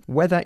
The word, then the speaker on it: whether: Okay, whether is spelled W-H-E-T-H-E-R, but it's pronounced exactly the same as the weather.